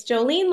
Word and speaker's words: Jouleen: So Instagram, Jolene Deering, and Facebook, same. The way I spell my name, J-O-U-L-E-E-N, it's Jolene like the song, but it's spelled wrong.